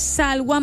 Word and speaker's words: Salwa: no sé si se pronuncia Salva, Salwa, tengo entendido que es Salwa Maloff, no, pero bueno, en fin, es Salwa Maloff, la nueva reina del carnaval 2027, el pueblo la pedía, la gente la quiere.